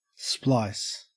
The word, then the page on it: splice: Australian English